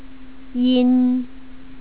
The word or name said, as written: yin